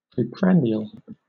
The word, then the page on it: preprandial: British English